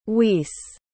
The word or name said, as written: wisse